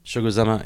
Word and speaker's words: Shogo Zama: Shogo Zama, yes.